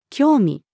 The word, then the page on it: 興味: female